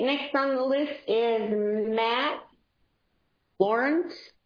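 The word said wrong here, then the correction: Lorentz: That's Lorenz. – Sorry. Yeah, the T is silent. They just threw that in there for fun. It's Max Lorenz, M-A-X, L-O-R-E-N-T-Z.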